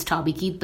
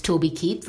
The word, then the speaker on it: Toby Keith: I would usually call him Toby Keith because it's T O B Y, so it's Toby Keith. But of course, the way he pronounces it, it's Toby Keith. So it is Toby Keith.